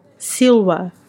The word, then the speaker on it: silva: pronúncia diretamente da minha, do meu professor de latim, silva, né? Porque eles não têm o V, eles não têm o som de V em latim, que significa floresta.